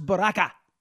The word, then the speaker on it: Baraka: A guy named Raz Baraka rhymes with caca.